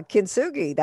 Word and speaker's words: kintsugi: So what is this kintsugi and how would we apply this philosophy in change? I don't even know if I said it right. – Yeah, kintsugi. That's great.